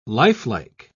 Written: lifelike

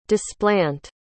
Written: displant

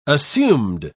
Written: assumed

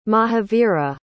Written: mahavira

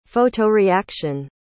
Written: photoreaction

